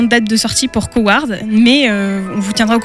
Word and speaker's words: Coward: le film Coward, j'espère que je le prononce bien. – Oui, c'est bien.